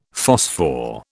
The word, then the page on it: phosphor: British English